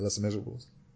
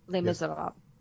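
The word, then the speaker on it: Les Misérables: I mean, we had a whole movie of people who can't sing, who sang in Les Miserables. So it happens. That's how you pronounce that, right? Les Miserables. – Les Miserables.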